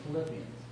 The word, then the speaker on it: Philippians: Philippians. – Isn't that what I said? – Yes, you said it right.